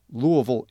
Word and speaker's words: Louisville: By the way, Louisville, Kentucky, yes, Louisville is the correct local pronunciation, is a tad more than 100 miles east of my hometown of Evansville, Indiana.